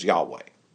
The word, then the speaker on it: Yahweh: This phrase then, YHWH, if you stick in a couple of vowels, you can pronounce it as Yahweh.